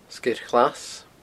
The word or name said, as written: Sgeir Ghlas